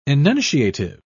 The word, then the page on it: enunciative: British English